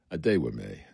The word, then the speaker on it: Adewumi: Hey, Adewumi, y'all say it right. Adewumi, like a day with me, a day with me.